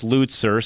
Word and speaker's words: Lutzer: I had your last name pronunciation wrong. It's Lutzer. So I apologize, Dr. Lutzer, for not saying that correctly.